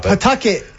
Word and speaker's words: Pawtucket: It's pronounced Pawtucket.